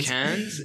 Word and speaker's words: Cannes: And the one at Ken. – Yeah. – Ken. – Ken. – Ken. – Ken. – Ken. Ken. Ken. Is that? – Oh, Cannes. Yeah.